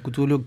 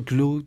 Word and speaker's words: Cthulhu: Sí, ¿Cthulhu sí, en realidad no tiene una pronunciación exacta en nuestro idioma, supuestamente, porque se basa en la obra de Lovecraft. De H.P. Lovecraft. – Sí. – Y bueno, sí, puede ser Cthulhu, Clue y demás.